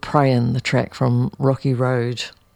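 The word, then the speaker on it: Rockie Rode: Before we come to Finn and Demo Gods, I'm going to play you an artist from New York called Rocky Road. Rocky, like the ice cream, but it's spelled R-O-C-K-I-E, and Rhodes like the microphone, R-O-D-E. So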